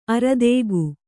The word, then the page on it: ಆರ್ಥಿಕ: Kannada